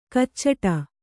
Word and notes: ಕಚ್ಚಡ: kannada; kaccada

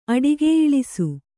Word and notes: ಅಡಿಗೆಯಿಳಿಸು: Kannada

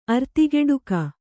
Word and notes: ಅರ್ತಿಗೆಡುಕಿ: artigeḍuki

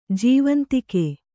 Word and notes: ಜೀವಾಂತಕ: jīvāntaka

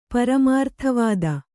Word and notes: ಪರಮಾರ್ಥವಾದಿ: paramārtha vādi

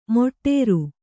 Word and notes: ಮೊಟ್ಟೇರು: moṭṭēṛu

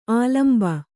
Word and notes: ಆಲಂಬ: ālamba

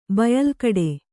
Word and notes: ಬಯಲ್ಕಡೆ: Kannada

